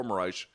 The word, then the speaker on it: Moraes: By the way, it turns out you can pronounce it Moraes or Moraes.